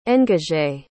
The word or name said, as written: engagé